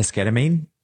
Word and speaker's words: esketamine: And esketamine, it sounds like the word ketamine because it is ketamine.